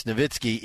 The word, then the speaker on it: Nowitzki: Yeah, I know it's how, but it's also like Nowitzki and Nowitzki, right? It's Nowitzki, even though it's a W, and people constantly mispronounce it.